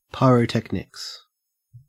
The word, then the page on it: pyrotechnics: Australian English